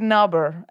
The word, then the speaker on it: Nobber: It was just outside Nobber. It's a little tiny place. Yeah, exactly. – Is that spelled with a silent K at the start? Or does it start for an N? – N-O-B-B-E-R.